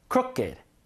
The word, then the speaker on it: crooked: crooked. 요거는 삐뚤삐뚤이란 뜻이에요. 이리 붙여 가지고 형용사 만든 건데 crooked 이렇게 읽어야 돼요.